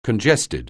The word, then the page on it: congested: anglais